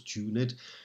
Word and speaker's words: tuned: and note incidentally that tuned here has two syllables, tuned, they do but sweetly chide thee who confounds in singleness the parts that thou shouldst bear.